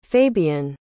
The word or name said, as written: fabion